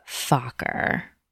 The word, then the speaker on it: Fokker: It's pronounced Fokker. F-O-K-K-E-R. Fokker. – You're saying in German it's Fokker? – In German and in every pronunciation, it's Fokker. – Interesting. Okay, I thought it was Fokker in German. – How do you pronounce it? – Just like it's spelled. F-O-C-K-E-R.